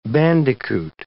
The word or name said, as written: bandicoot